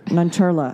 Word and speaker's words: Nancharla: Yes, she's voiced by Aparna Nancharia. Nancharla. I'm sorry.